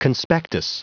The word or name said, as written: conspectus